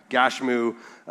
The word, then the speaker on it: Gashmu: Gashmu is the Hebrew pronunciation or Hebrew wording for Geshem, the Arab that we've been talking about all along.